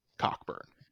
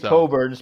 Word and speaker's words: Cockburn: It's not pronounced that way, even though that's how it's spelled. It's Kofi. – It is spelled Cockburn, and therefore I am pronouncing it Cockburn. – So Kofi Coburn's parents should be very happy.